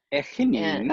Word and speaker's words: Echinina: Echinina. And, oh, you say it so much better. and I put the stress on the wrong syllable.